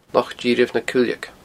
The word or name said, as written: Loch Dithreabh na Cuileige